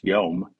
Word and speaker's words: yom: Number two, the same Hebrew word day, pronounced yom, is used as a period of time longer than 24 hours.